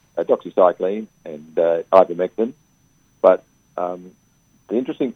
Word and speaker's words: doxycycline: and doxycycline. Is that how you say it? From the doxycycline. – Yeah.